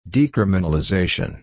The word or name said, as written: decriminalization